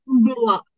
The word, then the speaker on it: Blois: Blois.